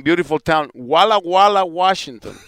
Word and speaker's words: Walla Walla: I just wanted to hear somebody say Walla Walla, Washington.